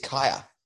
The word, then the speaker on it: Kya: Is it Kaya? Is it how you say it? – I thought it was Kyra. Is it K-Y-A, actually? It's Kaya. – Kaya, yes, K-Y-A.